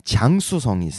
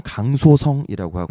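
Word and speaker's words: 장수성이 (江苏): 네, 샹하이가 있고 그 다음에 장수성이 있습니다. 한국어 발음은 강소성이라고 하고요.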